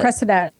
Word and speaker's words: Cressida: And then I think that gets furthered with, um, I'm not sure how you pronounce her name, Cressida? Cressida? the Cressida.